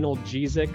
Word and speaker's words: analgesic: Alcohol was used for pleasure and for what's called an analgesic. Right, that's how you say it, analgesic.